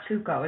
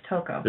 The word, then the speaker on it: Toko: It's not Tuko, it's Toko. – So it's Toko who turns out to be Genocidal Show, and she, basically whenever she faints...